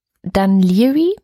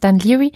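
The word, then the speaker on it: Dún Laoghaire: Was auch ganz in der Nähe von Dublin ist, ist Dunleary, heißt, wird es ausgesprochen, wird ganz komisch geschrieben, muss ich in die Show Notes packen, aber es heißt Dunleary.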